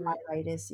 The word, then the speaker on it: myelitis: So you have a condition that is called transverse myelitis. Did I say it correctly? And we went over myelitis. Yes, you did.